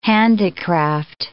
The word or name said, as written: handicraft